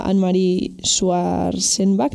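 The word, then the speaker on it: Annemarie Schwarzenbach: Y bueno, pues Anne-Marie es una novela gráfica que gira en torno a la fascinante biografía de la reportera suiza Anne-Marie Schwarzenbach, no sé si lo he dicho bien. – Sí, está bien, se acepta.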